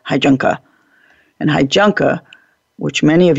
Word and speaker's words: Heijunka: But the Hijunka, which is a Japanese word, it's spelled H-E-I-J-U-N-K-A, Hijunka.